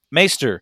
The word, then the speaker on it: Maester: Like but you have amongst all the different factions in kingdoms and clans, you have these Meisters? Am I saying that right? – Maester. Again, it's take a normal word and add like a letter to it or change a letter.